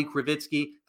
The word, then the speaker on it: Krivitsky: Mine is at Bobby Krivitsky. The last name is spelled K-R-I, V as in Victor, I-T-S-K-Y.